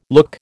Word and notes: look: American English

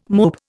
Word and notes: mope: Indian

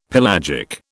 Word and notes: pelagic: British English

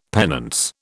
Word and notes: penance: British English

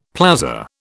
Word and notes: plaza: British English

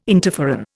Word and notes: interferon: Indian

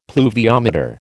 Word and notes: pluviometer: American English

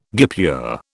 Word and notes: guipure: British English

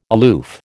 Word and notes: aloof: American English

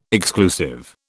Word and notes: exclusive: British English

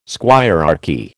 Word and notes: squirearchy: American English

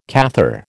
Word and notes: Cathar: American English

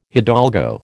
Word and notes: hidalgo: American English

